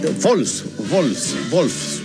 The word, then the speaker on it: Wolfsburg: No, ese Wolfsburg, porque la W se pronuncia como V corta, ¿no? O V, ¿no? Se dice V.